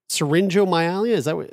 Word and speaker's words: syringomyelia: and so in talking about the syringomyelia, is that how you pronounce it? – Syringomyelia.